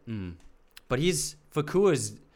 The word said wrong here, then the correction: Fuqua: Did I say his name? Fakua? – Yeah, Fakua. – Fakua? – Fakua. – Fakua? I think it's Antoine Fakua. – Fuqua. – Yeah, it is Fuqua.